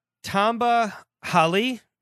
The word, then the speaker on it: Tamba Hali: Tamba Hali? Yes. Is that how I pronounce it? – As far as I know, that's correct.